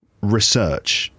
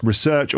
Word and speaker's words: research: Now, you research, this is a noun. The noun can be pronounced research or research. There's two ways to pronounce the noun. The verb is always pronounced research, to research something.